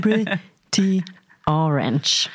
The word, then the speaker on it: Pretty Orange: Okay, Pretty Orange, Pretty Orange. Oh, wie du Orange nicht aussprechen kannst. Ja, so ist aber richtig.